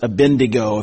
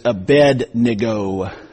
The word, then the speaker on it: Abednego: By the way, I hear everybody pronounce it Abednego. If you look at it carefully, it's Abednego. Abednego.